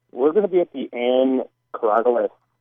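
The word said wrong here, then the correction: Korologos: Gallery. I'm sure I'm saying that wrong. – I think it's Ann Coralogos, I think, the gallery. – Coralogos.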